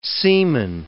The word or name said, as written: semen